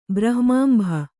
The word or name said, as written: ಬ್ರಹ್ಮಾಂಭ